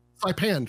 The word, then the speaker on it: Saipan: Is it Saipan? – Saipan, thank you.